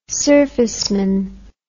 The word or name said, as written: surfaceman